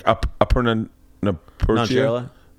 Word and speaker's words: Aparna Nancherla: Like Aparna Nancherla? – What?